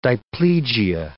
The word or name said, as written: diplegia